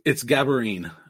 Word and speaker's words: Gabarine: It's Gabarine. When I taught, I would always tell the kids, it almost rhymes with tambourine. Just say tambourine and change the beginning of it.